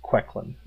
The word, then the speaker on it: Quecklin: Yes, my warlock's name on the record is pronounced Quecklin. That's with an E.